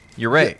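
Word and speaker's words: urea (幽霊): Now, the pronunciation in Japanese is very close to the Spanish. Mm-hmm. So, if I'm going to be asshole, I'm going to say urea.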